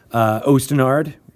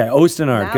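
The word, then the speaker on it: Osten Ard: Ostinard, did I say it right? – Yes, you did. – Okay, Ostinard. Good. All right. Now I can say it the other way too, and that's also all right.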